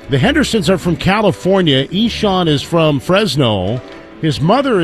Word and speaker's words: Ixan: He goes by Eshawn, which is spelled I-X-A-N, pronounced Eshawn.